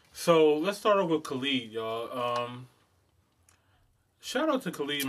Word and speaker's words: Khalid: Khaled. I don't know. – The nigga's name is Khalid, dog. – Khalid, I'm sorry, y'all.